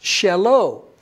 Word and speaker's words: shelo: In Hebrew, it's pronounced like mine is pronounced sheli. And the English word is his. His is pronounced shelo.